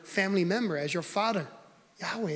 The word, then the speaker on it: Yahweh: YHWH, that's, you know, and the theologians, they're still, you know, uncertain of how you actually pronounce it, because the Jews have been so secretive about this. But we believe it's the name Yahweh.